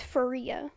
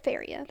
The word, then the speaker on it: Faria: I think I pronounced her last name wrong earlier. It's Faria. – Oh, okay. – I said Faria. That's how I read it in my head the whole time.